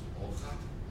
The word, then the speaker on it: hoja: But for those who have studied Spanish, the initial H in Spanish is completely silent. It has no phonetic value whatsoever. It's a holdover, it's a relic, it's an outage, it's there to cause confusion and make your life miserable. All right? The word for son is this word, pronounced hijo, hijo. The word for leaf is this word, H-O-J-A, pronounced hoja, hoja. And the verb to speak or to talk is this verb, spelled H-A-B-L-A-R, hablar.